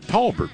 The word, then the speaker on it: Talbot: That's just like people in Talbot pronounce it Talbert, which I don't understand, never will.